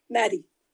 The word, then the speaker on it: Madi: is called Mad Eye. Is that how you say it? Madi. Madi, Madi sorry.